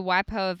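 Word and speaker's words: waipio (Waipiʻo): waipio valley so i don't know yes.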